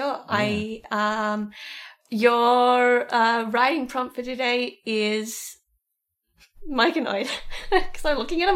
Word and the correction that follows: Myconid: Do you mean Myconid? I haven't wanted to correct you, but you've been saying it wrong.